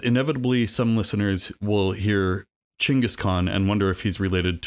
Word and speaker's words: Chingiss Khan: You may have heard of him referred to as Genghis Khan, but it's generally agreed that Chingiss Khan is a more accurate pronunciation, so that's how I'll be referring to him henceforth.